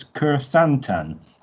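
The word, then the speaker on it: Krrsantan: And I've got to say, there was also that the Trins have a Wookiee gladiator, which I've looked for the pronunciation, and it's Kersantan, apparently.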